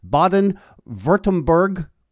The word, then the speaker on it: Baden-Württemberg: You as well have a Bachelor of Engineering in Aerospace, Aeronautical and Astronautical Engineering from the Baden-Württemberg Cooperative State University. Did I come close to pronouncing that right? – Perfect pronunciation.